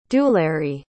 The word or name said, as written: dularee